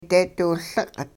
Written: deediwiLiq'

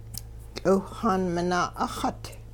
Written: tL'iwhxaan minaa' 'uxut

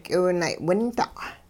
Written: k'iwunay'winda'

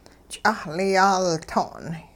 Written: ch'ahl yaaltong'

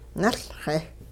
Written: nuLxe

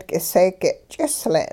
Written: k'iseege' ch'isle'n

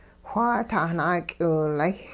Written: whaa tahnaak'iwilay'

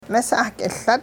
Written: misah-k'iLut

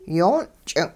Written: yoon' ch'ing'

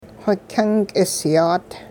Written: whikyungk'isyaat

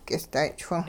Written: k'ist'ay'-chwing

